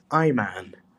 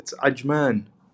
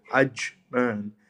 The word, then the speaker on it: Ajman: And I have never once ever heard anyone say that the smallest emirate is Ayman. It's not Sarajevo, man. It's Ajman. The J is pronounced as a J. You can really go at it. Ajman.